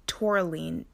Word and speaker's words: tourmaline: So, if it calls for um, black uh, molaline, I believe that's how you say it. Let me. Um, look it up so I can spell it. Uh, back black tourmaline, T O U R M A L I N E.